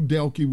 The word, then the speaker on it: Delke: You have a Nashville, Tennessee officer by the name of, let me make sure I get his name right, Andrew Delk, I believe that's how you pronounce his name, or Delke. It's spelled D-E-L-K-E, so I'll go with Delke.